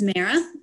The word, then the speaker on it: Mara: My name is Mara, and it's pronounced like maritime or marathon, or back when I was in elementary school, the kids would tease me with marijuana. So a lot of people mispronounce it, but that's how I pronounce it.